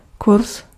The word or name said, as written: course